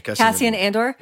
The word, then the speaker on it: Cassian Andor: Cassian Andor.